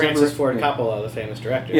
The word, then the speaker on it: Coppola: It's Coppola.